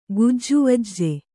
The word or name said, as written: ಗುಜ್ಜುವಜ್ಜೆ